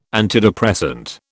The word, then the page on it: antidepressant: British English